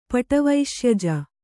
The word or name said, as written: ಪಟವೈಶ್ಯಜ